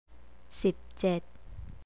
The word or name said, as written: sipjed